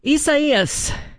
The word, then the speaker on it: Isaias: Isaias, that's how you pronounce it, has weakened to a post-tropical cyclone after slamming into Florida's Gulf Coast Friday night.